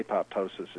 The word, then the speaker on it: apoptosis: And that is that multicellular systems, real multicellular organisms, have this thing called programmed cell death or apoptosis, although that the apoptosis is odd because the Greek it would be apoptosis, but the biologists mostly seem to pronounce the T. So anyway, it's A-P-O-P, well now I can't even remember how to spell it.